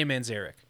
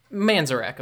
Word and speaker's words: Manzarek: How did you pronounce his last name? – It's Ray Manzarek. – Oh, yeah. I mean, that makes more sense than how I was pronouncing it in my head. How are you pronouncing it? – Manzarek, of course.